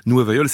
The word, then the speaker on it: Nueva Yol: Nueva Yol, ça correspond à la façon qu'ont les portoricains de prononcer le nom de la ville de New York.